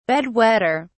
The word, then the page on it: bed wetter: British English